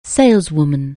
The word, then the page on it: saleswoman: anglais